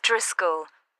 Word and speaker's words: Driscoll: It's Mrs. Driscoll.